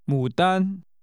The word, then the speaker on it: 牡丹: It's called 牡丹。 – 叫牡丹。牡丹。 – This name is pronounced with falling, rising, and high level tones.